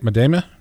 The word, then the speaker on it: Madama: Madama? – Jason? – Nah, Madama. – Madama. Gosh, dang it. – I know. – Sorry, man. Sorry.